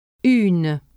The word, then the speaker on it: une: Let the air go through your mouth only when pronouncing the vowel sound u in une, and do pronounce the n. – U. Une.